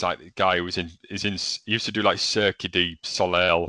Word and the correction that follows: Cirque du Soleil: That's terrible pronunciation. You guys always butcher the French language. – Cirque du Soleil, mate.